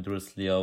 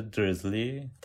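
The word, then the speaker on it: Dursley: البته بعد از کتاب صوتی مثلا، یا حالا فیلم چون شاید زیاد واضح مثلا نباشه به خاطر موسیقی، ولی مثلا حسین برای بریتانیاییش نوشته درزلی، تلفظ آمریکایی همون دروسلی که خب تندیسم که حالا هر جور نوشته هر خواننده ممکنه یه جور بخونه، یه جور بخونه.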